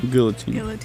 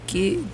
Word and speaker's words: guillotine: I thought it was guillotine. – Guillotine? It's not Spanish. – I know, but why would they put two L's? Guillotine. – Because that's how it's spelled.